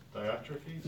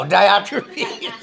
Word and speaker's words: Diotrephes: I always call it the narcissistic pastor disorder, like that NPD, like that guy over there in, Diotremius? Over there, and how do you pronounce his name? Anybody? – Diotrephes. – There you go, Diotrephes.